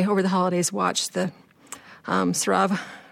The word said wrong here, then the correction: Saratov: I always say this wrong, Saratov approach, and I was really touched by that movie.